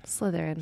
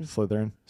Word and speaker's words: Slytherin: like from like the normal Slytherin kid. – How do you say it? – Slytherin. – Slytherin. – Slytherin.